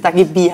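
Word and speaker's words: Dagi Bee: Dagi B. heißt sie. Dagi B. Like a bee, wie eine Biene.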